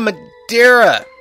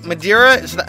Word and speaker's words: Madeira: Love town of Madeira. Wait, Madeira? Madeira. Yeah, that's how they pronounce it, right? Madeira. I was always pronouncing it Madeira, but I think it really is Madeira.